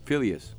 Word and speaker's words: Phileas: But she does it with her brother, is it Phineas? – Phileas. – Phileas, yeah. – Even though it's spelled F-I-N-N, I didn't know.